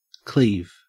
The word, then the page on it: cleave: Australian English